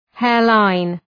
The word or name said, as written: hairline